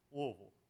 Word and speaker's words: Louisville: If it sounds like you got a rock in the middle of your tongue, it's you're saying it right, Louisville.